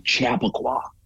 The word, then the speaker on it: Chappaqua: So I suppose the correct pronunciation, because it's a town in America, but the correct pronunciation would be Chappaqua.